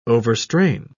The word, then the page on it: overstrain: British English